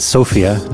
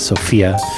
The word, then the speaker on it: Sofia: Thanks to FUNK for correcting my pronunciation on Sofia, not Sophia. – Sofia. – Yeah. See, I still don't get it right.